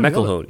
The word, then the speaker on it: McElhone: McElhone? How do we pronounce that, Tim? From Harry's New York Bar in Paris. – I think you nailed it.